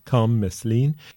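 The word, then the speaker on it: Kham Meslien: His first name is spelled K-H-A-M and then M-E-S-L-I-E-N, Kham Meslin.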